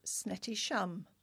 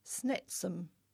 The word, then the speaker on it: Snettisham: All the broadcasters pronounced the place name as Snettisham, but I recall what may be the pristine original Norfolk pronunciation of that seaside place. The late poet laureate and broadcaster Sir John Betjeman, 1906 to 1984, used to pronounce it as Snetsam.